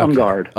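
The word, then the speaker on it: En Garde: And a couple songs on the, is it En-Garde? Is that how you pronounce it? – Yeah. Okay. En-Garde.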